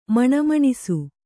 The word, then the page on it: ಮಣಮಣಿಸು: maṇa maṇisu